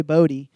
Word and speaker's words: Bode: B-O-D-E. I think Bodhi would have an I or a Y in there. – He can spell it however he wants. It's how it's pronounced.